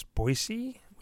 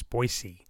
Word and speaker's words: Boise: By the way, that is how you pronounce Boise, with a s and not a z. It's the city of trees, and Bois, Bois, is the French word for trees. Just as a little geography lesson for you, it's Boise.